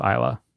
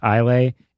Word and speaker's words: Islay: There are nine active distilleries on the island of Isla. – That's, I feel like. – And that is Islay. People might try to pronounce it Islay. It is Isla. That is how you pronounce it.